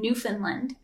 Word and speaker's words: Newfoundland: and they conducted research in two communities in Newfoundland.